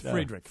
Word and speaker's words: Friedrich: I said fried Reich. – No, it's Friedrich. – Friedrich.